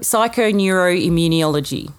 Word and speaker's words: psychoneuroimmunology: And it's interesting that last one, Can you pronounce it properly? – Psychoneuroimmunology. You did really well.